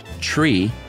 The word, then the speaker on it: tree: Three is pronounced tree, to not be confused with the antiquated prefix sri, S-R-I.